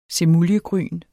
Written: semuljegryn

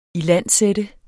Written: ilandsætte

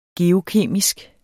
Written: geokemisk

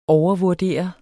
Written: overvurdere

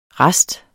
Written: RASTEN

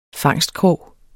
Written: fangstkrog

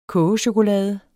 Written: kogechokolade

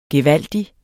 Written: gevaldig

